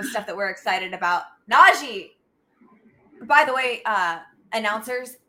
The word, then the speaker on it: Najee: He has said several times it's Najee, exactly yeah.